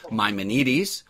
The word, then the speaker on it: Maimonides: So I'm going to move us along and say, unfortunately or fortunately, the great Jewish sage Moses Ibn Maimon, known by the mispronounced anglicized version of his Hellenized Arabic name Maimonides, or proper Greek, it would be called Maimonides.